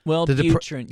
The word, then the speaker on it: Wellbutrin: However, I think Wellbutrin, is that how you say it? – Wellbutrin, yeah.